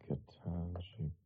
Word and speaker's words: Ketanji: I guess we'll flip it around to D.C., where we had more hearings for Ketanji Brown Jackson. Ketanji? Am I pronouncing that right? – I think it's Ketanji Brown Jackson, yeah.